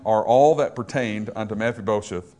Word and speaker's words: Mephibosheth: Referring to, again, we pronounce it Mephibosheth all the time, but the actual pronunciation is Mephibosheth.